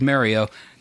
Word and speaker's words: Mario: Has Super Mario Brothers, which apparently we're supposed to pronounce as Mario. Just a little shout-out to my friend, @HotPikachuSex on Twitter. – That's how the Italians say it.